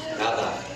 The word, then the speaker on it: nada: Nada. – Yeah, the D is pronounced like a TH, right? Because it's intervocalic, it's between two vowels.